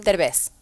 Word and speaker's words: Derbez: How do you say his last name? – Derbez. – Derbez.